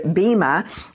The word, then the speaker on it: bema: Well, the word, the Greek word that normally people think it should be pronounced bema in the Greek, but we pronounce it in English bema. So bema is fine to do.